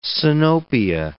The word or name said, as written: sinopia